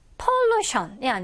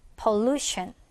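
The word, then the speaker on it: pollution: 그래서 여러분들이 이런 단어를 읽을 때는 션 앞에 오는 그 모음에다가 강세를 넣어주면 발음을 정확하게 할수 있죠. 그래서 얘도 pollution이 아니라 pollution이 되고요. 얘도 translation이 아니고요. translation이 되는 거죠. translation 바로 앞에.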